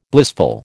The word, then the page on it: blissful: American English